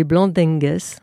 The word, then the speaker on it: Blandengues: Pour protéger leurs frontières des incursions des Portugais venant du Brésil voisin, ils ont créé le corps des Blandengues. Alors c'est là, je ne sais pas si c'est comme ça que l'on prononce. – C'est bien comme ça.